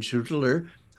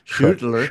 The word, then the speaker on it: Schüttler: So let's just take a look at one example, and that's the work of Schwilden and Schüttler. How'd you like that? I pronounced that German, Schüttler.